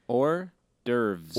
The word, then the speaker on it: hors d'oeuvres: They're more hors d'oeuvres. – Wow, I can't even say it right now. – Hors d'oeuvres. – Hors d'oeuvres, that's it.